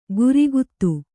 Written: ಗುರಿಗುತ್ತು

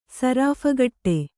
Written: ಸರಾಫುಕಟ್ಟೆ

